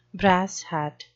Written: brass hat

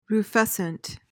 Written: rufescent

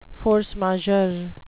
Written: force majeure

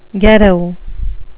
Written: ghetto

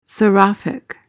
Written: seraphic

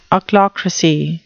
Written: ochlocracy

